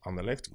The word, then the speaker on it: Anderlecht: Anderlecht. – Yeah, Anderlecht. Good. Standard. – Look at me with the right pronunciation.